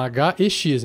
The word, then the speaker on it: Hex: Só que em inglês se escreve com H e X, então fala Hex.